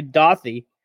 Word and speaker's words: Dadi: His name is actually Dothy, but it's spelled D-A-D-I, but it's pronounced Dothy.